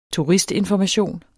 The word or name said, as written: TURISTINFORMATION